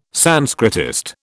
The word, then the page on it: Sanskritist: British English